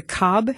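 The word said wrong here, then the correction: Cobh: Heritage Center? – It's actually Cove. – Cove. – Like a Lake Cove, yeah. – Like Siobhan, okay. – Because, yeah, like Siobhan, exactly.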